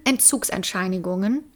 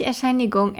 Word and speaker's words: Entzugserscheinung: Und deshalb leidet sie dann unter extremen Entzugserscheinungen. Entzugserscheinungen? – Nein, falsch. Entzugserscheinung heißt es. – Entzugserscheinungen. Habe ich es jetzt richtig gesagt? Entzugserscheinungen. – Nicht Erscheinigung, Erscheinung. Entzugserscheinung.